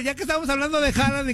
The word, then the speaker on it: Haaland: Es Haaland.